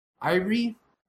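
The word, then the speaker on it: Ayre: like claiming that he's Satoshi Nakamoto, fucking has this guy, Calvin, I think his pronounced Irie, A-Y-R-E, I don't know, Calvin Irie.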